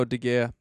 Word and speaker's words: De Gea: De Gea? How do you say that? The keeper for Man U? – Oh, De Gea. – De Gea? Yeah, there we go.